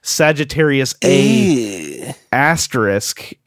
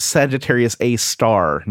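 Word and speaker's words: Sagittarius A*: Ron, who wrote, has anyone noted that from episode 227, Sagittarius A asterisk is pronounced Sagittarius A star, not A asterisk? – Oops.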